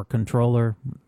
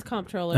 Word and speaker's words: comptroller: Bill Thompson, who's the former comptroller or controller, fucking silent P. He's comptroller. It is comptroller, okay.